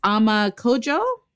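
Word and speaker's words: Ama Codjoe: Her name is Ama Kodjo. I think that's how you pronounce it. Like C-O-D-J-O-E.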